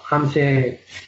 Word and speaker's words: Hamšík: O Marek Hamšík, que no, Hamšík sí que sé que se dice así, pero el nombre no lo recuerdo bien.